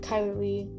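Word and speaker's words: Chyler Leigh: Kyler Lee. Am I saying her name right? I don't think so. Let me look. Yeah. Okay, I am saying it right.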